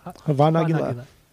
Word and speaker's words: Hava Nagila: Okay, so here's the their version, or their better version of am I saying it wrong? Hava Nagila? – Yeah, it's fine. Hava Nagila.